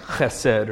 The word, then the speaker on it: Hesed: it's in English you would spell it H-E-S-E-D, Hesed, or it's probably like Chesed or something like that, but I'm not very good at that.